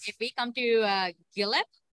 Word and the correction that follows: Guelph: right? That's how you say it? Or how do you say it? – Oh, well. – Guelph. – Yes. – Okay, Guelph.